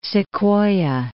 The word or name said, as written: Sequoia National Park